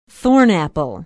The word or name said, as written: thornapple